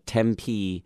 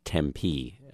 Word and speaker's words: Tempe: Tempe, Arizona Police Department. By the way, that's how you pronounce Tempe. It's not Tempe, as I always thought.